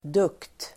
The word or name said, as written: dugt